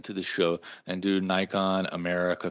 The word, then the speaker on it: Nikon: But their marketing to Americans said Nikon. The president of Nikon North America in interviews, he says Nikon. So the reason Americans say Nikon is because Nikon said Nikon to Americans.